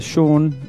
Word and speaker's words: Sean: It's uh, Sean, and my name is spelled S E A N, and you got it right.